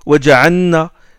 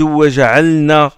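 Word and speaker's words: وَجَعَلْنَا: Ou aussi l'élision, il fait l'idram là où il ne faut pas le faire, il dit waqunna, waja'anna, au lieu de waja'alna, au lieu de prononcer le lam, il l'introduit dans le noun.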